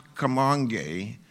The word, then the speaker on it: Kamonge: Now, I didn't go to college, but this was my college, and it's pronounced Kamonge, and it's a Kikuyu word meaning working together.